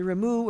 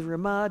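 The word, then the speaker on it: Rema: But what did the great Rabbi Moses Isserles, the Ramu or the Rama, depending on how you pronounce the acronym of his name, who is considered the greatest Polish rabbi in the entire history of Polish Jews, what did he say?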